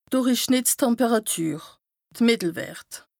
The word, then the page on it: d' Dùrichschnìttstemperàtür, de Mìttelwert: Bas Rhin; Strasbourg